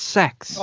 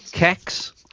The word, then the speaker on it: CeX: I saw an advert for CEX, and they pronounced it sex. – Oh, yeah. – I know. It's rubbish. – Why did they pronounce it sex? It's not fucking sex. – Apparently, that's how it's pronounced.